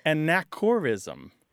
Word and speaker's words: anachorism: So A-N-A-C-H-O-R-I-S-M. Unfortunately, it sounds a lot like anachronism, so people will think you're making a mispronunciation, but it's anachorism.